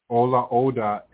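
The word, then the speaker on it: Olaudah: In her memoirs, Equiano, Ola Oda Equiano.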